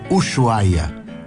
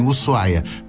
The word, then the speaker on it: Ushuaia: Eu, pessoalmente, digo Aspasuma, como também digo Ushuaia. E, às vezes, algum argentino dá risada porque eles dizem Ushuaia. Mas quando tu vai ver alguma locução mais antiga, diziam Ushuaia, aquele SH tem que servir para alguma coisa, não é possível.